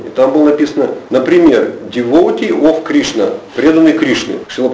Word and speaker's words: devotee: Интересно, что ударение в нем ставилось на последний слог. Слово пришло, вероятно, из французского, и говорили devotee. Я проверял в старых словарях, действительно, везде, именно в старых словарях, devotee, ударение на последнем слоге. Шрила Прабхупада стал использовать это слово, такое забытое, устаревшее, для обозначения преданного Господа. И стал ставить ударение, причем на втором слоге, devotee.